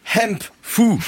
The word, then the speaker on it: hemp: C'est le Hemp fou. Est-ce que je prononce bien? – Oui, avec le H aspiré, très bien.